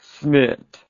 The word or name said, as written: smit